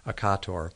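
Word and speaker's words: Occator: You'll often hear it pronounced Akator in other ways, but the correct pronunciation is Akator.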